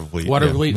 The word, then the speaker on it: Watervliet: Water Watervliet. Uh, uh, is that pronounced? – Water Watervliet. Yeah.